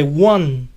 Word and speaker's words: one: Dicen que para ellos la pronunciación de one suena como si estuviera escrito con doble U inicial.